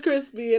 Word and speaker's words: Chrispy: My name is Crispy, and that's spelled C-H-R-I-S-P-Y.